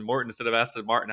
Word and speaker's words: Aston Martin: Aston Martin, but I, I somehow mispronounced. I said Aston Morton.